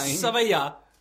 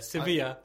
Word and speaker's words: Sevilla: Spain. Sevilla. – Yeah, okay. – That's how they say it over there. Sevilla.